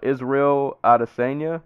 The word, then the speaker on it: Israel Adesanya: Israel Adesanya, if I'm saying that right.